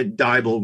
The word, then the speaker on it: Dybel: And that is how we got to meet Tanya Dybel, rhymes with Bible.